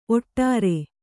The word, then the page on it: ಓಡ್ರ: Kannada